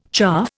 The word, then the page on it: chaff: Indian